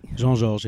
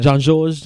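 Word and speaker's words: Jean-Georges: Jean Georges, yes. Jean Georges, Jean Georges.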